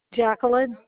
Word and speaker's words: Jacqueline: Jacqueline, J-A-C-Q-U. E-L-I-N-E P-R-O-T-H-M-A-N. – Okay, and so that was M-A-N? – Yes.